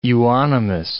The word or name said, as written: euonymus